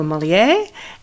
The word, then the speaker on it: Molière: and the other two writers were Molière, and is that how you pronounce it? – That's right.